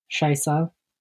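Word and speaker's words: Scheiße: Shaisi? – Uh, Shaisa. – Yeah, Shaisa.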